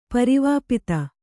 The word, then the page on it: ಪರಿವಾಪಿತ: pari vāpita